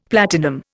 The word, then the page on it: platinum: Indian